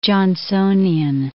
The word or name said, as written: Jonsonian